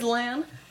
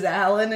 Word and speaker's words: Lan: I just said Lan. – We do say it as Lan, but like it's funny to read it as Alan and make fun of him.